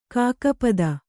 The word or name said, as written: ಖಗಪತಿ